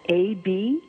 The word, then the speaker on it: Aburdene: It's spelled Aburdene.